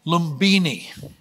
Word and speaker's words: Lumbini: Get the pronunciation right next time, okay? Lumbini in Nepal.